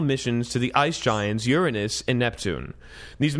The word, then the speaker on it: Uranus: Up until now, NASA has never paid too much attention to Uranus, or Uranus, as it's supposed to be pronounced.